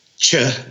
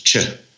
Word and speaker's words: Che: It's pronounced Che, like chocolate, ooh, like Che Nide. – Ooh, like chocolate. – In Argentina and in other parts of Latin America, some will do Che and some will do She, like chocolate. – Chocolate. – So yeah, the correct pronunciation for Argentina is Che.